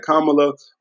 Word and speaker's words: Kamala: We got Kamala, you got to make sure you say it right, Kamala on the ticket with Joe Biden.